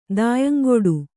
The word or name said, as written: ದಾಯಂಗೊಡು